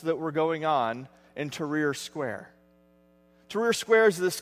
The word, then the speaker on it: Tahrir: In 2011, during the Egyptian Revolution, there was a group of Christians in, I'm going to get this right, because I was complimented after the first service that I actually pronounced this right, Tahrir Square in Cairo.